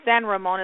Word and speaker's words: San Ramon: So, in the Mexican, you'd say San Ramon is how you say Saint Raymond.